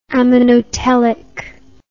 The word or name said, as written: ammonotelism